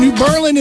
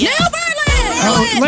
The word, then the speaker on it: New Berlin: Jeremy Ray from New Berlin is 49. – Oh, you didn't say it right. It's in all caps. – New Berlin!